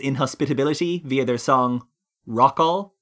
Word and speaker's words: Rockall: Where they basically pronounce Rockall like f-all. Because that's what's there.